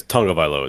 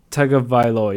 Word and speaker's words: Tagovailoa: Like, I think it's Tagovailoa is how you pronounce it, but I could be wrong. – Tagovailoa, you're right. I've got it up here.